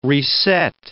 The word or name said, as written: reset